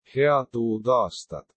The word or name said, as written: head uut aastat